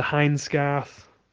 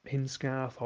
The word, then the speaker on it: Hindscarth: And then you work your way up Dale Head, up onto Hindscarth, which I'd say it like that, not Hinscarth or Hinscrath as I've heard some people say, and then on to Robinson, and then that drops you down to Newlands.